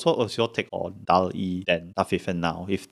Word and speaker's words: DALL-E: DALL-E? Is that how you pronounce that? – I think so. I think it's a wordplay from WALL-E, DALL-E. – Yeah, the Disney Pixar thing.